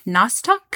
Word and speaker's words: Nostoc: Another scientist said the meat shower was actually a N-O-S-T-O-C, Nostoc? – Nostoc.